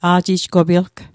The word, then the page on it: a'jijgopilg: Mi'gmaq